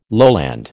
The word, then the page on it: lowland: American English